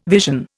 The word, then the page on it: vision: Indian